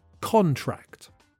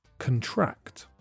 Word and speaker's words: contract: When the word is a noun, the stress is on the first syllable and you can hear the o sound, contract. When it's a verb, the stress is on the second syllable, and the weak first syllable has the schwa sound, contract.